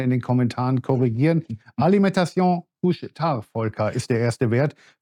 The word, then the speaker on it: Couche-Tard: Ich glaube, deine Aussprache war perfekt. Es ist ein kanadischer Wert und ich glaube, er kommt auch aus dem französischen Teil äh, Kanadas. Also äh, Kuhstar ist schon richtig ausgesprochen.